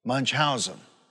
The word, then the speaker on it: Munchausen: It's Munchheim syndrome or something. What is it? Munchausen.